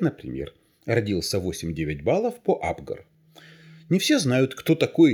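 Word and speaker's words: Апгар: Абгар – это фамилия такая, именно с ударением на первой букве «А» – Абгар.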